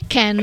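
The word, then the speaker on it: Kean: come si pronuncia? – Perché io so Ken, che è Ken, sì. Per me è Ken. – Ken. Infatti, io ho sempre detto Ken, però esatto, alcuni dicono Kin, vabbè.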